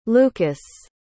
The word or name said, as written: lucas